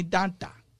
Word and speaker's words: Danta: D-A-N-T-A. People call me Danta. Danta.